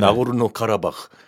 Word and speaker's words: 나고르노 카라바흐: 나고르노 카라바흐.